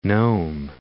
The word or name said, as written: Nome, Cape